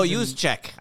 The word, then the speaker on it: Juszczyk: And Kyle Juszczyk? I always struggle with that name. – Kyle Juszczyk, Juszczyk? There's all those J's. – No, Juszczyk. I don't have to check. Juszczyk.